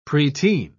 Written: preteen

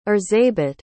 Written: erzsebet

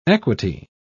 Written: equity

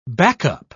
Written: backup

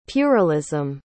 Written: puerilism

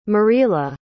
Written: Myrilla

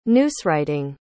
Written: newswriting

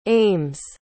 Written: ames

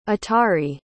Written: atari

